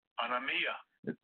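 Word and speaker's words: Anomia (ἀνομία): Anomia. – Anomia. Okay. Anomia. That's what it means. Anomia. I mean, that's how you pronounce it.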